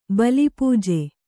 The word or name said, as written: ಬಲಿಪೂಜೆ